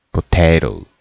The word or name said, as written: potato